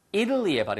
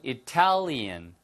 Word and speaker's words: Italy: 발음 하나 주의하라고 말씀드린 적 있는데요. Italy의 발음이 Italy. Italy. 아시겠죠? 예. 그 다음에 이게 I로 바뀌고 A에 붙으면 Italian. 그래서 Italy, Italian.